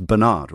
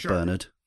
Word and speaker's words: Bernard: And I realized, sorry, I was pronouncing, me pronouncing it the English way there, but yes, he's American, so it's Bernard, right? Not Bernard.